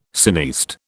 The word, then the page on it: cineaste: British English